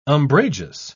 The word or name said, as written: umbrageous